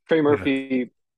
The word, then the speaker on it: Trey Murphy: The fact that Trey Murphy, I believe, I hope I'm getting his name right. – Trey Murphy, yeah.